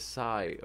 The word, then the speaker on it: psi: Said by the way, you should pronounce psi as sigh.